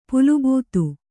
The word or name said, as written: ಪುಲುಬೂತು